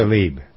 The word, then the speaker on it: Tlaib: and is it Talabi? I never pronounced her name right yet. I don't Tlaib. – Think. – Tlaib. – Yeah. Tlaib. Okay.